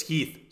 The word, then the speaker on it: Heath: It's Heath.